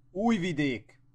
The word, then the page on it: Novi Sad: Hungarian